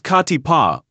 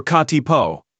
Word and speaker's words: Katipo: Katipo, pronounced Katipa or Katipo, Latrodectus katipo, is a species of cobweb spider found only in New Zealand.